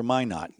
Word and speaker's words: Minot: I don't even know if it's pronounced Minot State. – Oh, yeah. It was Why Not for Minot.